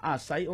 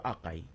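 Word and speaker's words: açaí: Ano ma-pronounce na? Asay or akay? Berry. – No, akay berry.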